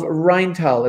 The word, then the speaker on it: Rheintal: who's the head of the International School of Rheintal. Am I pronouncing that right? – Pretty close. It's good enough.